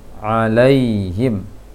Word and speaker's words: alaihim: Kemudian alaihim masih terbaca lay, seharusnya ala, alai, laihim, alaihim, alaihim.